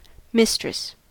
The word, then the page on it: mistress: American English